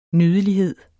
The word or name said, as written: nydelighed